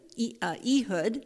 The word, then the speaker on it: Ehud: Ehud is how it's pronounced.